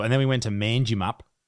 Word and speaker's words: Manjimup: I was pissing off the whole town because I kept going, oh, fucking Manjimup. Stupid name. – I still don't think that's how it's pronounced. – It's Manjimup. Manjimup. Mangy, they call it.